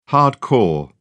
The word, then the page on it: hard core: British English